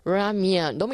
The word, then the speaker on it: 라면: 라면. 너무 이렇게 할 필요 없어요. 너무 라면을 굴려서 발음하지 마시고요. 라면. 이렇게 똑부러지게 발음을 하셔야 됩니다.